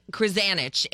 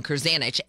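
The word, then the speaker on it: Krzanich: Krasanich. And I pronounced his name wrong. Krasanich.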